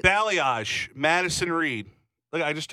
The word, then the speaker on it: balayage: Balayage. Mm-hmm. Like the country Bali. – Yeah. – Yaj. – Balayage. It's not spelled that way, but apparently that's how you say it.